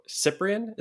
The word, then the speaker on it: Ciprian: I have with me today Cole Parkinson and Piero Ciprian.